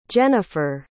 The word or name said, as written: jennyfer